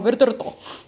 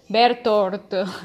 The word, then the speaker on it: Bertolt: Ahorita sí lo puedo pronunciar bien, es... Bertortortorto, no, mentira. En español vendría a ser algo así como que Bertolt, en inglés Bertolt, y en japonés que lo pronuncie como Bertorto. Bertolt.